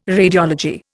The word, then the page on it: radiology: Indian